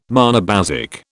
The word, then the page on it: monobasic: British English